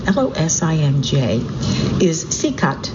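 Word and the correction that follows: Čikat: Chikat. It's nice, he's giving me pronunciations as I go. So the jewel of Losinj is Chikat, C-I-K-A-T,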